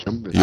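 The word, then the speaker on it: Joomla: Øh, altså, hvis jeg lige skal nævne nogle i flink, så er der jo øh, Drupal, øh, .NET Nuke selvfølgelig, øh, jo, Jumla, er det ikke som man udtaler det? Jumla. Jumla. – Jo.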